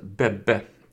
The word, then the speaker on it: Bebe: Jag vet inte om man ska uttala Bebbe eller Bebe, Bebe, Bebe, Bebe, jag vet inte. Bebbe är det, B-E-B-E. -E. e.